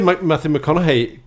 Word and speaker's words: McConaughey: Uh, men, men, du uttalar ju Matthew McConaughey korrekt.